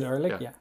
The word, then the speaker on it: Ehrlich: Everyone seemed relatively positive, except second favorite Carl Boy after David Sims, David Elric. He was lukewarm. Did I say his name right? Not David. – David Ehrlich, yeah.